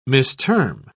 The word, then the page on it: misterm: British English